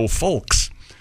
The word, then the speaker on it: Fulks: That was, of course, Joe Fulks. – How did he spell that? – F-U-L-K-S.